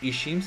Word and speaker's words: ICHIM: ISHIM se escribe ICHIM.